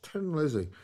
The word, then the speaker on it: Thin Lizzy: And he's famous in the, you know, that song by Tin Lizzy, or Thin Lizzy. It's spelled that, but it's pronounced Tin Lizzy because they're Irish.